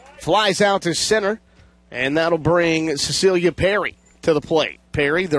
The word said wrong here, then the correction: Piercy: Apologize for the mispronunciation of the last name. It's Piercy, not Perry.